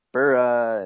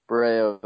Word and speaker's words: Boreo: I mean, you're actually doing a fabulous job at pronunciation as well, because most people, we get a lot of Boreo or how do you say that? Or is it Bura? And so Boreo is the correct pronunciation there.